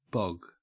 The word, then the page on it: bog: Australian English